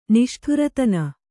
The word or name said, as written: ನಿಷ್ಠೂರತನ